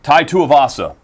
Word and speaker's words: Tai Tuivasa: Uh, Tai Tuivasa he is fighting Greg Hardy.